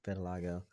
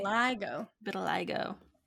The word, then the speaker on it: vitiligo: Is that Vitiligo? Yeah, Vitiligo. Apparently Michael Jackson had it too. – Yeah. Vitiligo. Google pronunciation. – Oh, okay. – Vitiligo.